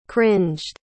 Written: cringed

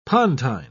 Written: pontine

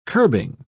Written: curbing

